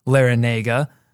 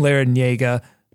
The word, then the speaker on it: Larrañaga: Yes, so apparently his grandfather, so Jim's grandfather, which would be Jay's great-grandfather, is of Cuban descent, and Jay's father, Jim Larrañaga, took the Basque Cuban pronunciation of his name, so that is the tilde Niega, but then somewhere along the way he dropped it, but then when he went to coach for Miami, the Cuban pronunciation came back again.